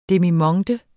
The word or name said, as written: demimonde